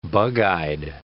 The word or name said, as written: bug-eyed